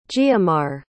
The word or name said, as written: giomar